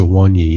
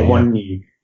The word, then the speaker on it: Awoniyi: Okay, I'll start with the one that I don't know anything about, and that's Awino, is that how you pronounce it? – I think it's Awoniyi, yeah, but anyway. – Awoniyi, yeah.